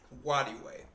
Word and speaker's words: Nwadiwe: Nwadiwe. The end is silent.